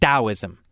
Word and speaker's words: Taoism: Why didn't we just spell it with a D? I don't know. It comes from, you know, Chinese words, so I guess they wrote it, they heard it wrong, and I don't know. Anyway, it's pronounced Taoism. And the book is called Taoism, Way Beyond Seeking.